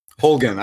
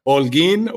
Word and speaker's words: Holguin: Holguin. I know people were speculating on my Latino roots. So it's, you could say, Holguin or Holguin.